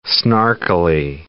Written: snarkily

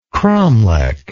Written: cromlech

